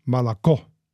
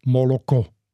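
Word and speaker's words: Moloko (молоко): Aber die Aussprache auf Russisch ist Malako. Also das O wird so reduziert zu einem Ä, Malako. Und im Ukrainischen bleibt das aber nicht reduziert, Moloko.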